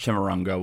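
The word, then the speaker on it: Chimurenga: But he would call this era the third Chimarrunga. I think that's how it's pronounced. – Chimichanga. – And Chimichanga. I think it's Chimarrunga. – It's Chimarrunga. I'm just fucking around.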